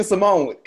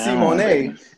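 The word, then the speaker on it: Symoné: It's like Raven Simone. She has an exotic U on. It's supposed to be Simone, and she's like, the accent is silent.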